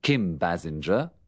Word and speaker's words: Basinger: Por ejemplo, he aquí tres formas diferentes de pronunciar la G. – Kim Basinger, Richard Gere, Jessica Lange.